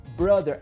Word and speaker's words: brother: Agora, presta atenção como que pronuncia. Brother. Não é nada de bro, tá? É brother. E tem que colocar a linguinha pra fora pra fazer o som do TH, ó.